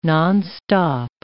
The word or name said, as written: nonstop